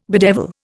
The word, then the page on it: bedevil: Indian